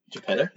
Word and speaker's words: Geppetto: Geppetto? Is that how it's pronounced? – Yeah. – Like Cornetto?